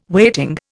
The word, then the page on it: weighting: Indian